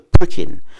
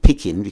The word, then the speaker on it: pricking: And it's in council that she does what we call the pricking, and it's not picking, because a lot of people will get that wrong, and it's called a pricking service.